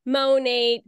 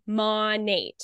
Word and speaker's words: Monat: It is from the two words modern and nature. So it's actually pronounced Monate.